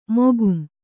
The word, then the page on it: ಮಕಾಂ: makām